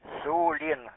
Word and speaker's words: Сулин: Я сейчас один раз вам по буквам передам город, вот, я смотрю, вы неправильно произносите его. Сергей, Ульяна, Леонид, Иван, Николай, Сулин.